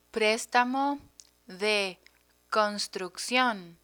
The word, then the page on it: prestamo de construccion: Spanish